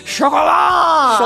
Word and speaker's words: Chocolat: Joining me is author, journalist, podcaster, and imaginary rabbit, Amy Jones, to talk about Joanne Harris's 1999 hit, Chocolat! – Chocolat. That is how you say it in France.